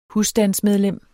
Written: husstandsmedlem

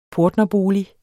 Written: portnerbolig

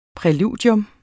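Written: PRÆLUDIERNES

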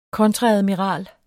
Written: kontreadmiral

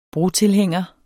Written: brotilhænger

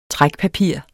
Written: trækpapir

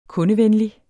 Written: kundevenlig

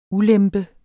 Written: Ulempen

